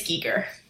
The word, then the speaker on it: Giger: And then, is it Giger or Geiger? – It's Giger.